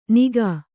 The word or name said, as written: ನಿಘ